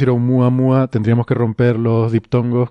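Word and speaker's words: Oumuamua (ʻOumuamua): porque dijimos que entonces se pronuncia Oumuamua, pero he estado viendo estos días en conferencias por ahí que dan colegas en Estados Unidos que realmente la pronunciación correcta es Oumuamua que de hecho suena mucho más hawaiano.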